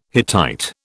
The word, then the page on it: Hittite: British English